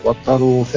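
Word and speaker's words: 暴太郎: ね、まあ変化球、まあ変化球っていう意味では、あの、アバタロー戦隊っていうのがあったから、まあね、あの、アバタロー戦隊。 – そうそう、ボー太郎と書いて、アバタローって読ませるっていう。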